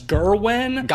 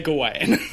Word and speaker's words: Gawain: I'm going to mention right now that in the movie, everybody pronounces his name as Gerwin. – Gawain, yeah. – I'm not going to do that. I'm going to say Gawain.